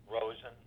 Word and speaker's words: Rosen: Michael Rosen, R-O-S-E-N.